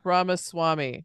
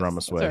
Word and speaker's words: Ramaswamy: Ramaswamy right now is getting a little bit of love. He's up a little over 7. – Ramaswamy. – Ramaswamy. Sorry, I said yes. Ramaswamy.